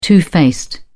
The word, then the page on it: two faced: British English